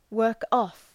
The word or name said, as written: work-off